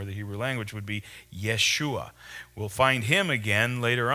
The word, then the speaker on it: Yeshua: And those who came with Zerubbabel were Yeshua. That's a Jewish or Hebrew pronunciation, a Hebrew name, Jeshua, for the name Joshua or the name Jesus, Yeshua.